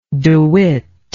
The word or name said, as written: dewitt